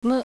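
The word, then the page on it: u: Japanese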